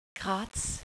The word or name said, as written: Graz